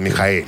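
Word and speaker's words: Michael: Это не Майкл, Михаил. Михаил. Михаил. Ну, Майкл не, вообще нет. пишется. Ну, а всегда его называют Михаил.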